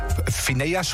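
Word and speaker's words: Finneas: Aaron Rowe zie ik, Lucas Graham en Phineas, zo moet ik het zo uitspreken. – Phineas, de broer van Billie Eilish in dit geval, die zijn allemaal weg.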